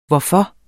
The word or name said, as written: hvorfor